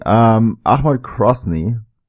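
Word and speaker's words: Akhmat Grozny: Ahmad Krosny. I actually said this right? Yeah, it's Ahmad, not Tarek.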